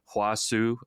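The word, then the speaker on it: Hua Hsu: Like I'm reading Hua Su.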